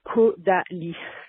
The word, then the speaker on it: Caudalie: Um, oh, and for all the Dorees out there, there's also a Kodalis bar, and it's pronounced Kodalis.